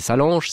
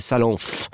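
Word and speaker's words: Salanfe: On se dit, c'est quoi, c'est Salanche, c'est Salance, c'est non, c'est Salanf.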